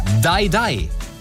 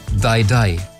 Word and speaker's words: Dai Dai: Dai Dai. Hier ist meine Spontanreaktion, nachdem Shakira das am Donnerstagabend veröffentlicht hat. – Zwei von drei Ländern sind Englischsprechende und da hieße Dai Dai ausgesprochen stirb, stirb.